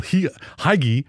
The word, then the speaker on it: hygge: And probably one of the bloggers or the people that I follow online had made reference to what Americans would call Hygge. H-Y-G-G-E. I mean, if I was an American not knowing this word, you would American pronounce it Hygge. – Okay. – But H-Y-G-G-E, it's a Danish term that designates what I talked about in our opening hook.